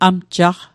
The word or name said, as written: amjaqq